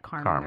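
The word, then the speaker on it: Karmien: It's K-A-R-M-I-E-N. – Mm. – But From the news coverage of this case that I watched, it sounded like her mom was pronouncing it Carmen or something.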